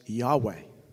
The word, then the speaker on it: Yahweh: Most people pronounce this four-letter Hebrew word, which is spelt Yod-Heh-Vav-Heh, as Yahweh. Yahweh.